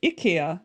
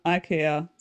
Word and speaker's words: IKEA: And in Scandinavia, it's IKEA, not IKEA.